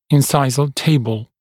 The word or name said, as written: incisal table